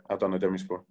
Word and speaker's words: Adana Demirspor: Is it Adana Demirspor? – Demirspor. Yeah, Adana Demirspor.